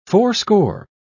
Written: fourscore

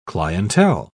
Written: clientele

